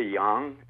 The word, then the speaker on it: Jong: You Are There by Erica Jong. It's J-O-N-G. Is that how you pronounce it? – Yes.